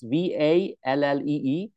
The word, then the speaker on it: Vallée: And it's actually, the source is a really neat author, Jacques Vallée. I'm butchering his name, but it's Vallee.